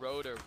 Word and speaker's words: Roederer: It's like Roederer. – Let me look him up. – Roederer.